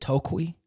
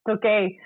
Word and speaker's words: Tokay: My favorite song on your album, I'm about to butcher this, is it Tokui? – Tokay.